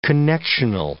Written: connectional

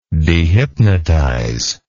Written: dehypnotization

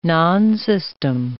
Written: nonsystem

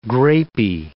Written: grapy

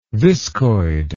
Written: viscoid

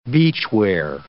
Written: beachwear

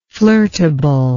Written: flirtable

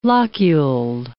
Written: loculed

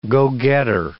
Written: go-getter

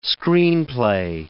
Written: screenplay

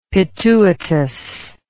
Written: pituitous

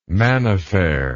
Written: manifer

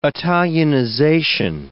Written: Italianization

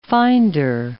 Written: finder

